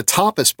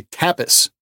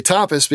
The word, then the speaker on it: tapas: And we had seen a tapas bar. Now, you guys say tapas, but I didn't know that. We say tapas because that's how it's correctly pronounced. If you do it with Spanish, tapas.